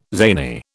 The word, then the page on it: zany: British English